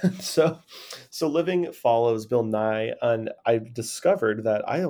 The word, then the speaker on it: Nighy: Um, so it's pronounced Bill Nighy, apparently.